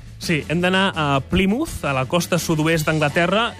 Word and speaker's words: Plymouth: Plymouth. – Jo hagués dit Plymouth, perquè és una Y, però no és Plymouth. – És que enganya, per això. – Plymouth